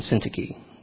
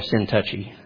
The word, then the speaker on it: Syntyche: Well, in Greek, it's Euodia, not Euodia, Euodia, and Syntyche. In modern Greek, it'd be Syntyche, the way they pronounce their Ada. But in English, we can just say Euodia and Syntyche. It'd be the easiest. Okay? Just don't call her Sin Touchy.